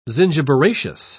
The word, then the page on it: zingiberaceous: British English